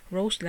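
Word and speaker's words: Rosé: Me parece que una de ellas será Rose, la de Blackpink, según se pronuncia, también estaría Shine It, el chico de O'Neill, y aparente y alegadamente parece ser que también estará la conocida Sora de Mamú.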